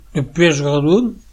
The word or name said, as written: Redon